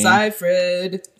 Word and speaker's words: Seyfried: Amanda Seyfried. – Seyfried. – I just learned how to pronounce her name. – Seyfried.